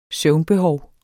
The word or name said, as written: søvnbehov